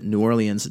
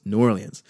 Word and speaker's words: New Orleans: Your own fucking mayor pronounces it New Orleans just like I do. So I don't actually care anymore about your opinions. The mayor pronounces it my way. I'm pronouncing it my way. It's New Orleans.